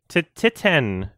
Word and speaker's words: Titane: Is that how you say it? – Titane. Yes. Well, because it's a French title that translates to titanium, I believe.